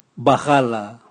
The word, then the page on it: bwagala: Raga